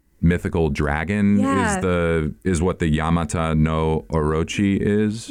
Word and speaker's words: Yamata no Orochi: Yamata no Orochi.